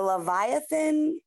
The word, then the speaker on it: Leviathan: And I had read about the Leviathan spirit.